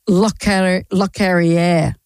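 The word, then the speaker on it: Locorriere: His Lockery. He has passed away. Let's say his name correctly, she said, looking for the piece of paper. Lockerrière.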